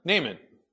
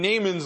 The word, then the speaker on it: Naaman: Let's think about Nahum. Naaman. There we go. I'll get his name right. Naaman.